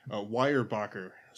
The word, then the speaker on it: Weyerbacher: I have here, from watch me butcher this name, Weyerbacher, Sunday Morning Stout. – It's actually pronounced correctly.